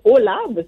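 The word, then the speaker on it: AU/LAB: Et je pense que ça se prononce OLAB, c'est ça? A-U euh, slash L-A-B? Oui, c'est ça.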